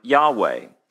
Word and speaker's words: Yahweh: Most scholars, there's some debate about how it was originally pronounced, because around the 2nd or 3rd century BC, Jews stopped saying the name. But most modern scholars agree that it's pronounced like Yahweh.